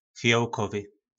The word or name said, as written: fijolkovy